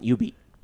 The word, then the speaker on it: Jubeat: These are 2003's Karaoke Revolution, 2007's Jubeat, and 2010's U-Beat. – Dance Masters. – Careful, the J is silent. Jubeat.